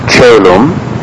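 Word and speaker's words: caelum: So, for instance, our word chelum, C-A-E, diphthong L-U-M, you have the soft sound, ch. For the word caro, C-A-R-O, caro, the C is hard because it is followed by an A.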